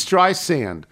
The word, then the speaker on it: Streisand: But even better, from a Morning Brew newsletter I received this week, my name isn't spelled with a Z. It's Streisand, like sand on the beach.